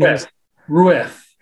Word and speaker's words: Jacques Rueff: Jacques Rueff.